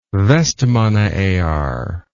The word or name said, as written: vestmannaeyjar